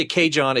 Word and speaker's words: CaJohn: When I say Cajon, it's spelled like Cajun, but with John, J-O-H-N, so C-A-J-O-H-N.